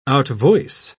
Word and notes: outvoice: British English

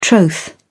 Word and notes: troth: British English

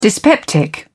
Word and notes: dyspeptic: British English

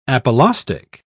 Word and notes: apolaustic: British English

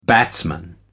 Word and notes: batsman: American English

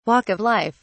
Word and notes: walk of life: British English

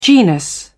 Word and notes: genus: British English

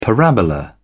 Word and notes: parabola: British English

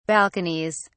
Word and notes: balconies: British English

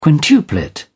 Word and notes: quintuplet: British English

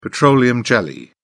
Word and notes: petroleum jelly: British English

